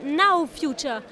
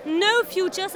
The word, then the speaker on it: Now Future: Il faut prononcer Now Future. Sinon, on croit que ça signifie No Future, ce qui signifie pas de futur. Or, l'idée, c'est bien de dire Now Future, le futur, c'est maintenant.